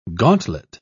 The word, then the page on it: gantlet: British English